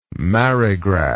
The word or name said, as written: marigraph